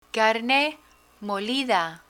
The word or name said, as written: carne molida